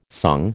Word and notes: sung: American English

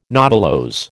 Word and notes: nodulose: American English

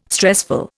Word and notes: stressful: Indian

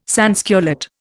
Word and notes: sansculotte: Indian

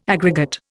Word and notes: aggregate: Indian

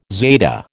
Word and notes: zeta: American English

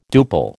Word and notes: duple: American English

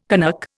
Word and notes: Canuck: Indian

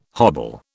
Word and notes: hobble: British English

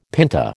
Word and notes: pinta: American English

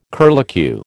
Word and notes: curlicue: American English

